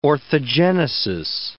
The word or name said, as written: orthogenesis